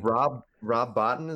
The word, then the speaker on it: Bottin: Rob Bottin, is that how you say it? – Yeah, dude.